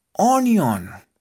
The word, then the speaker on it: onion: Ahora, necesitamos también onion, cebolla. Y esta también se escribe muy sencillo. O N I O N. Onion. Como si escribiéramos unión, pero en vez de la U, una O.